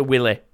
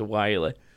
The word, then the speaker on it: Wily: Dr. Willy. I always think that it's supposed to be Dr. Wily, but I always call him Dr. Willy just because he does look a bit like a giant penis.